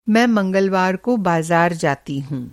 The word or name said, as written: मंगलवार